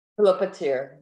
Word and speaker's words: Philopateer: Philopateer, yes.